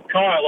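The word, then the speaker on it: Khyll: Oh, Kyle, obviously.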